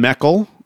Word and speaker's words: Meckel: Stephen Meckel, who I like to pronounce it Merkel, but apparently I've been wrong for the all the time.